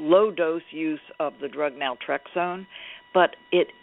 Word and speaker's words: naltrexone: Now, in your book, you talk about low-dose naltrexone, if I'm pronouncing it correctly.